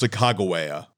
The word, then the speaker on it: Sacagawea: She's 6 months pregnant, and her name is Sacagawea. In some interpretations, her name is pronounced Sacagawea.